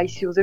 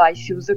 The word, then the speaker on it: Icews: E il mio nome d'arte è Icius, mi chiamo Alice, però il mio nome d'arte è appunto Icius, che si scrive I-C-E-W-S.